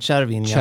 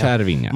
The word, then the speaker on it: Cervinia: Nej, om man då ska vara PT eftersom du frågar, så är det Cervinia. – Cervinia, bra. Alla svenskar och jag inkluderad säger ju Cervinia.